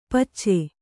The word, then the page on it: ಪಚ್ಚೆ: pacce